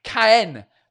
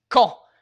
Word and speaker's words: Caen: I mean, if you were to read it out, you'd pronounce it Cayenne, like cayenne pepper, almost. Caen. But it's Caen. Like, it's not Caen, which is idiot. It's Caen. The same way you would pronounce the word when.